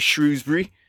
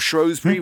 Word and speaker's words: Shrewsbury: You're here me, myself, and a 0-0 draw is Shrewsbury, or Shrewsbury, whichever one you want to pronounce it as, is all right with me.